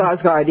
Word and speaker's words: Skarsgård: Skarsgård, yeah, yeah.